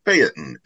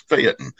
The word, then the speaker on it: Phaeton: And I said, it's Phaeton, isn't it? It's not pronounced the way that the Greek god is. And he goes, oh, no, it's Phaeton. And I called the Peterson Automotive Museum, and they said, it is absolutely a Phaeton.